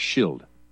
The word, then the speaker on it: Rothschild: and thus became Rothschild. Commonly, the last syllable is pronounced as if it were child, but it is in fact Schild, or shield.